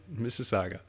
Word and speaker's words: Mississauga: And the city that she was born in, Mississauga.